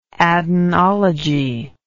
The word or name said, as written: adenology